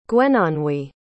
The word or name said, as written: gwenonwy